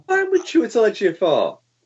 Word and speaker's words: Chiwetel Ejiofor: you forget that it's Chiwetel Ejiofor did I say that wrong? I probably did. – No, that's good.